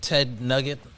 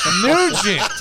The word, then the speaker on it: Nugent: Is it Ted Nugget? – Nugent.